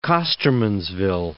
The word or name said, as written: Costermansville